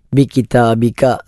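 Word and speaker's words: ബികിതാബിക (بكتابك): ആമൻതു ത ആണ്, ബികിതാബിക സാധാരണ ക ആണ്, രണ്ടും ഖാഫല്ല കാഫാണ്.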